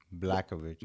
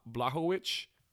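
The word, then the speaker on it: Błachowicz: Blakovic. No, man, we were saying it wrong this whole time. It's uh Bla- Blahovic.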